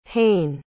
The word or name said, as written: hayne